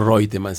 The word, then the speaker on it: Reutemann: En cuanto a números, yo podría decir que es una, una provincia tipo Reutemann, o Reutemann si quieres pronunciarlo bien, en su idioma original del alemán.